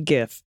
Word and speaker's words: GIF: Hard G GIF.